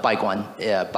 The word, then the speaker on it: Baiguan: But Baiguan by Robert Can you say that more slowly? – Yeah, it's spelled B-A-I-G-U-A-N.